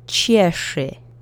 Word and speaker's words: cieszy: And the last word is cieszy. You'll need your sh phoneme again from the previous word. It's supposed to rhyme.